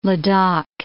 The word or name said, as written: Ladakh